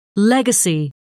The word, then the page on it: legacy: British English